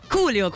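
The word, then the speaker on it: Coolio: Questo l'ho detto bene. – Culio, sì, ho detto bene. Più facile Culio, sì.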